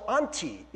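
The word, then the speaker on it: anti (ἀντί): So anti is how you pronounce it in the Greek, right?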